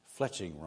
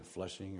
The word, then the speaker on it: fletching: I kept pronouncing fletching wrong. I kept saying fleshing or something.